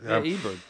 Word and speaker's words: Ebert: Ebert, yeah, it's okay.